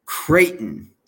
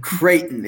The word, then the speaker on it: Creighton: Creighton. – You're saying it wrong. – Creighton. – It's like crayon except for Creighton.